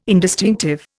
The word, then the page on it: indistinctive: Indian